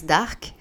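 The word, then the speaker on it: DDVRKK: alors en fait, ça se prononce Dark et ça s'écrit double D, V, R, double K.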